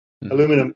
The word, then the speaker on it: aluminium: By the way, aluminium is the correct pronunciation. Go look it up. And because I knew just in case, we're educating. God. Aluminum is an acceptable alternative for Yanks and Americans.